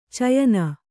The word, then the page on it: ಚಯನ: cayana